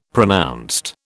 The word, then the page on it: pronounced: British English